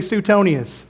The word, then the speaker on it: Suetonius: Everybody say Suetonius.